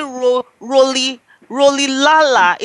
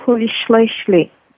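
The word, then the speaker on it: Rolihlahla: So it's not, if I pronounce it Roli Lala, it's American. How is it pronounced in the African way, please? It's pronounced Holy Schleshley.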